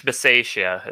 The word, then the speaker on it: Bisaccia: Rich Bisaccia is how you say his last name.